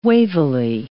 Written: wavily

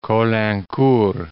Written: Caulaincourt

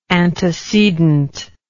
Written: antecedently

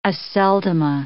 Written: Aceldama

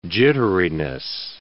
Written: jitteriness

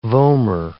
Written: vomer

